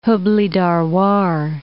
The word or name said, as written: Hubli-Dharwar